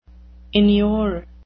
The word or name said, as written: inure